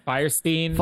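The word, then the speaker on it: Fierstein: And we meet Harvey is it Firestein? Is that how you say his name? – Firestein.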